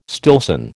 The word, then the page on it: Stillson: American English